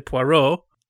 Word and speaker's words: Poirot: He's going to say Poirot.